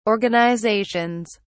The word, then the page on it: organizations: British English